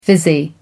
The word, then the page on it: fizzy: American English